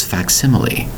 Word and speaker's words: facsimile: Today's word is facsimile. Facsimile. We have four syllables for the word facsimile, spelled F-A-C-S-I-M-I-L-E. I'll say it again. Facsimile, spelled F-A-C-S-I-M-I-L-E.